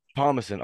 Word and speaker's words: Thomasson: Is it Thomasson or how you pronounce it correctly? – Thomasson. – Thomasson.